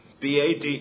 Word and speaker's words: bade: The word bade is pronounced bad in verse 4.